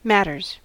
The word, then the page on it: matters: American English